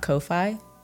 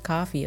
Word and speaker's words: Ko-fi: Use the Ko-Fi. No, so apparently it's not Ko-Fi. I think someone in the comments corrected me and they were like, I thought it was still pronounced coffee.